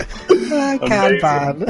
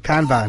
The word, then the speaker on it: Kanban: It's not pronounced that way, is it? – Kanban. – Kaban. That's right, isn't it? – No. – Is that not right? Have I got that wrong? – Yeah, no, it's wrong. Kanban.